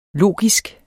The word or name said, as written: logisk